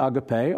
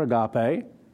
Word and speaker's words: agape: So, but whoso keeps his word, truly in them is the agape or agape of God perfected. There's so many ways of pronouncing this. Sometimes people get bent out of shape because they hear a certain pronunciation. Like, that's not how you say it.